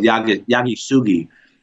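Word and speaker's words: Yakisugi: And it's an ancient Japanese wood charring technique called Shosugiban, which is kind of a Western pronunciation of a native Japanese word called Yagisugi.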